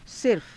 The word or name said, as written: sirfa